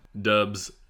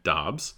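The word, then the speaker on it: Doubs: Yeah, I know we talked about Romeo Dubs, who now, have you heard that he actually pronounces his name Dobbs? He said in a press, or he was asked by someone, and he said, well, you know, it's actually Dobbs, but he was like, didn't really want to correct people.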